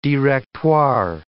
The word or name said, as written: Directoire